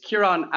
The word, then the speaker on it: Ciaran: So it's Kiran Avitabile, and Kiran is spelled C-I-A-R-A-N. Avitabile, my surname is A-V-I-T-A-B-I-L-E.